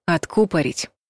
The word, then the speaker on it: откупорить: Но если вдруг захотите блеснуть знанием слова «откупорить», то есть выдернуть пробку, не забывайте про правильное ударение. Ставьте его на букву «У» — «откупорить».